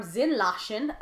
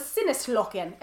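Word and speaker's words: Sinneslöschen: And yes, I looked up the pronunciation, because based on how it's spelled, I thought it was Sinneslochen. And I thought I was going to be so cool with my German Sinneslochen. And then I was like, Google Translate, how do I say this? And it was like, you dumb bitch, it's Zinlachen.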